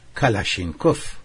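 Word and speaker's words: کلاشنیکف: سپاهی کلاچنیکوف به دست است و یا به تلفظ خودشان کلاشینکوف.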